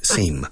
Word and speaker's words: seam: Seamstress, because seam is escrito seam. Se pronuncia igual que parecer, seam.